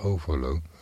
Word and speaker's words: Overloon: Ja, zo heet het toch, overloon. En trouwens, ja, je legt de klemtoon verkeerd. Het is overloon. Zei ik dan verkeerd dan? Nou ja, overloon. Over in het klein en loon in het groot.